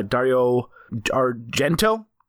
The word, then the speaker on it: Dario Argento: And then my favorite new movie I've seen so far this year, Demons, which is a 1985 movie produced by Dario Argento.